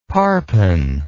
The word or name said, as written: parpen